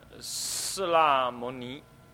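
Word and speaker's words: 室罗摩拏: 释拉摩尼，这个不应不适当念作罗哈，那个罗的念成拉才对，啊，那个你看那个大悲咒那个南无和拉，哆啦达拉呀呀，那个拉都是都是用罗字边旁边加个口字。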